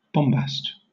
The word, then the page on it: bombast: British English